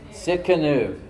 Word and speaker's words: Tsidkenu: Sit canoe. It sounds like I sit in the canoe, okay? It's spelled T-S-I-D-K-E-N-U.